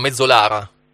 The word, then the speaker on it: Mezzolara: Mezzolara? – Sì, sì.